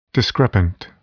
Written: discrepant

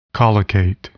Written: collocate

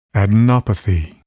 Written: adenopathy

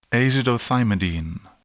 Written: azidothymidine

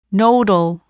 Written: nodal

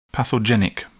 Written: pathogenic

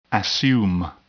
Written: assume

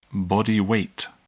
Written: body weight

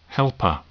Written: helper

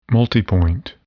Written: multipoint

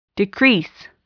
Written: decrease

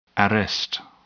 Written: arrest

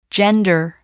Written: gender

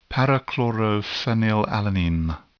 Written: p-chlorophenylalanine

